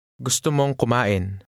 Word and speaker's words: gusto mong kumain: Now try to say, you want to eat. – Gusto mong kumain. Mo. Mm. Mong. Gusto mong kumain.